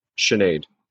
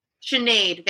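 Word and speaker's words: Sinéad: How do you pronounce it? – Sinead. – Sinead, thank you.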